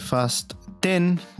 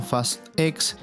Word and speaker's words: Fast X: Exactamente, se trata de Fast 10, como dijo el mismo director, que se debe pronunciar el nombre de esta película, no Fast X o Rápidos y Furiosos 10.